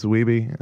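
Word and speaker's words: Wiebe: There's even a scene where, like, you know, fucking Walter Days in King of Kong is going, Steve Weeb, everybody. He's like, Weeby. It's Weeby. And then, like, they're like, oh, we've been saying it wrong the whole time.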